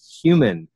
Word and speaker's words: HVMN: He is president and founder of what used to be NutriBox and is now HVMN, but it's pronounced human.